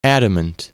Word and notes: adamant: American English

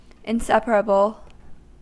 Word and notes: inseparable: American English

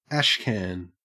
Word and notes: ashcan: Australian English

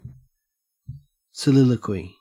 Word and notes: soliloquy: Australian English

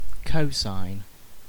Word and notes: cosine: British English

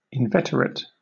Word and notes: inveterate: British English

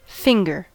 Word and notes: finger: American English

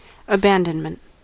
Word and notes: abandonment: American English

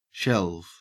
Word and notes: shelve: Australian English

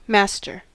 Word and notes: masterfully: American English

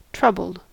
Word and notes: troubled: American English

